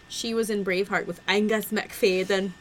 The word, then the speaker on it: Angus MacFadyen: who was in Braveheart with Angus MacFadyen. That's how you pronounce it in his native language, Michelle.